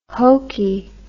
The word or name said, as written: hoky